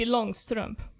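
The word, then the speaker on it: Långstrump: Or how do you say In Swedish, it's Pippi Longstrump. – Longstrump.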